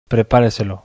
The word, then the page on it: prepareselo: acento lexical; bizkaia